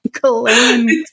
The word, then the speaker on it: Ghislaine: Ghislaine. – Her name is spelled like Glizane, but it's pronounced Ghislaine.